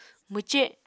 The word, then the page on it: mwce: Apatani